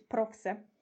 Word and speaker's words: Prowse: Amanda Prowse, czyta się, pisze się Profse.